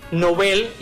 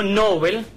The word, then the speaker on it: Nobel: En buen idioma, en varias ocasiones hemos aclarado que este apellido de origen sueco, en su lengua de origen, es una palabra aguda y en español también es una voz aguda. Por tanto, lo apropiado es que se diga Nobel y no Nobel, aunque esta pronunciación llana esté extendida incluso entre personas cultas.